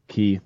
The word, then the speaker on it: Kee: I'm pretty active mostly on Twitter, so you can follow me at Will Key. My last name is spelled K-E-E.